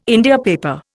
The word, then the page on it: India paper: Indian